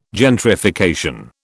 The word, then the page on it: gentrification: British English